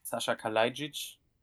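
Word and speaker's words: Saša Kalajdžić: Yeah, so first and foremost, if I'm not mistaken, the correct pronunciation would be something like Sasha Kalajdzic. – Okay. – hope that's correct. – It's Sasha, not Sasha.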